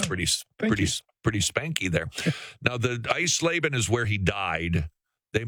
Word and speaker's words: Eisleben: No, he died in, I'm gonna butcher this, Iselbein? I mean. – Eisleben.